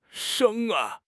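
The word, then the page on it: reng1: tone 1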